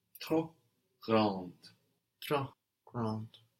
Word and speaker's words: grand: Le garage est trop grand. Le garage est trop grand. Good, now we don't hear the final D. No, we get G-R-A-N-D, and that D is silent.